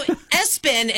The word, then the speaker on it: ESPN: So Espen is what they say, they pronounce it, okay.